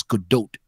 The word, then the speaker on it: Gadot: Gadot is not it. It's Gadot.